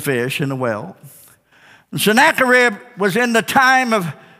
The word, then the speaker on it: Sennacherib: There was a king by the, by the name of Zeracharib, Sennacherib, Sennacherib.